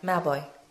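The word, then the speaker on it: Mauboy: Okay, so last song, I'll Be There by Jessica Mowboy. So, did I say her name wrong? – No. – Mowboy.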